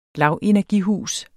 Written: lavenergihus

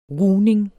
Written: rugning

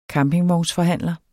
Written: campingvognsforhandler

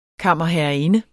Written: kammerherreinde

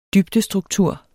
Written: dybdestruktur

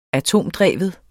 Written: atomdreven

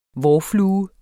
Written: vårflue

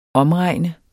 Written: omregne

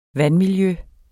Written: vandmiljø

